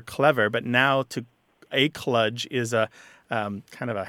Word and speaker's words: kludge: And then the other word, just to dispense with that pretty quick, kludge is how it's often said. The kludge is how it looks like it's spelled, K-L-U-D-G-E,